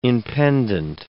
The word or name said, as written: impendent